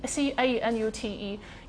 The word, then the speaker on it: Canute: 首先，先讲这个 Canute 吧，C N C A N U T E，有的书哈是发是拼成 C N U T，也一样啦，你也是念它念它念作 Canute。